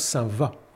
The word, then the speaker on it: Saint-Vaast: Alors, je le sais parce que moi, je suis originaire du nord de la France et à Soissons, il y a un quartier Saint-Vaast qui se prononce Saint-Va.